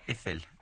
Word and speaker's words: Eiffel: Es la pronunciación correcta. Sí, sí, sí. Eiffel.